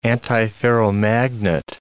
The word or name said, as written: antiferromagnet